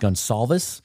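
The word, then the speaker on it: Goncalves: Matt, I believe it's pronounced Gonsalves out of Pittsburgh,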